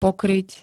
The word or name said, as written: pokryť